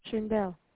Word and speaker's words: Shainvel: S-H-A-I-N-V-E-L. – And I'm sorry, how do you say your name? – Shindel.